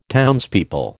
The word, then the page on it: townspeople: American English